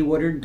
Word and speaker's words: Woodard: Woodard, W-O-O-D-A-R-D.